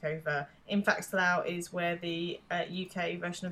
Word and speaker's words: Slough: Slow? S-L-O-U-G-H? – Slough.